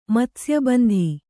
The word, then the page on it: ಮತ್ಸ್ಯಬಂಧಿ: Kannada